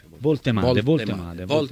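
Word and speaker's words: Woltemade: Voltemade.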